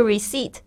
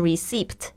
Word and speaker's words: receipt: 特别注意一下这个 receipt，它虽然里面有一个 p，但是 p 不发音，不要念成 receipt，不要念错了。